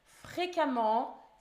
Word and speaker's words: fréquemment: Fréquemment, ça s'écrit F R E Q U E E M M E N T. Ça se dit fréca, mais ça s'écrit avec un E.